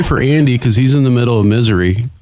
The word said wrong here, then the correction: Missouri: I'm sorry. It's pronounced Missouri.